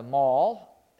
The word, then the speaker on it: marl: They call it mall. It's spelled M-A-R-L, but they call it like the shopping mall.